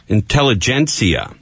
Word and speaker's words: Intelligencer: Is it the Intelligencer? Is that how you pronounce that? – Intelligencer. – Yeah. – Even though it's spelled G-E-N-C-E-R.